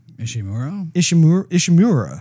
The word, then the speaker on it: Ishimura: Ishimura.